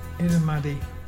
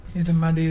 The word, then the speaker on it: Irrumardi: Irrumaddy. – What is that? – Irrumaddy is brim, the fish brim, and it's spelled I-R-R-U-M-A-R-D-I. Can you say Irrumaddy?